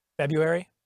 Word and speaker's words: February: Did you put the R in there? – February? – Yeah, most people... – Yeah, I don't pronounce the first word. No one pronounces that R.